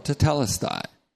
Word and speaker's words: tetelestai: So it was the Father who said, finished, tetelestai, or tetelestai. You want to be true to the accent that falls on the second syllable.